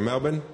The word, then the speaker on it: Melbourne: I have to say it properly. Melbourne, I think is how we say it, yeah. My wife went to university here for a while. She always tells me I don't pronounce the R.